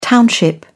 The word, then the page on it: township: British English